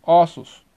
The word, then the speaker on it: ossos: Alguns substantivos, além da desinência S, trazem como marca de plural a passagem do O fechado, O, para o aberto, O. Na pronúncia, exemplo, posto, postos, osso, ossos, Corpo, corpos.